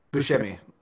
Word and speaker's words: Buscemi: Buscemi.